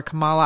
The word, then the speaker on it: Kamala: But with regard to Senator Perdue, apparently his faux pas, his error in his mispronunciation of Kamala Harris, I've always called her Kamala. I hear some people say Kamala. I don't know. I don't even know if I've ever heard her say her name. Well, actually I have, and it was never Kamala.